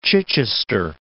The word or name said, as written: Chichester